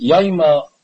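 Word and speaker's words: Yoma: Chazdei Hashem Yisborach, we were zoiche in these days, and in this month, in these months, to learn Mesechtes Yume. Rabbis Mishchus Chachamim says that it's called, correctly it should be pronounced, Yoimo.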